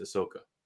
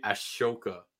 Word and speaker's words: Ahsoka: But yes, everyone pronounces it differently, but I'm pretty sure it's Ahsoka. – It's Ahsoka, yeah. – Yeah, a lot of people say Ashoka. – Yeah. – And it sounds funny, but I guess maybe if you try to read it phonetically or something like that.